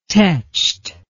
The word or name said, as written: tetched